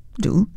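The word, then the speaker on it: août: And the month of August is D apostrophe A-O-U-T, pronounced do. And the word doubt is pronounced do.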